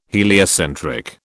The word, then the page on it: heliocentric: British English